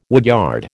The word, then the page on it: woodyard: American English